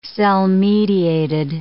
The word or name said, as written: cell-mediated